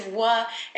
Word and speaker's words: Oaxaca: It's tricky. Yeah, it's Oaxaca. – Oaxaca? – Like Oaxaca. – But it's spelled O-A-X-A-C-A.